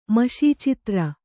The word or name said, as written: ಮಷೀಚಿತ್ರ